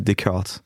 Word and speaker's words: Descartes: Alors la prononciation est assez simple, c'est Descartes. Pour les Américains, c'est assez simple. On a Descartes pour les Espagnols.